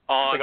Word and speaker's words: Andre: Andre. – Like Andre the Giant.